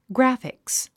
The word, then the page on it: graphics: ɡrǽfiks; グラフィクス